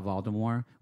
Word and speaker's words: Voldemort: Like, I'm sure that they're going to talk about Voldemort, which is actually no T is pronounced.